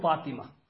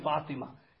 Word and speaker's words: Fatima: He says, Karisa, please teach me how must I take the name Fatima. And I have to pronounce the ta properly. I can't just say Fati.